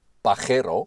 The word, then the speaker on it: Pajero: Pajero, daar uitgesproken als Pajero. Pajero.